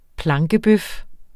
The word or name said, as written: plankebøf